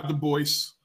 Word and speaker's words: Du Bois: Waters Jr., sent a memo to Du Bois, am I saying it right? Du Bois? – Nah, he went by Du Bois.